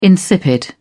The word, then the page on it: insipid: British English